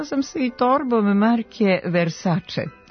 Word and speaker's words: Versace: Iako se prezime poznate italijanske porodice Versace čita sa ć, ovakav izgovor nema uporište u pravopisu. Prema pravilima transkripcije, italijansko c se ispred vokala e i i čita kao č, Versace.